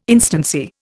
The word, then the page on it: instancy: Indian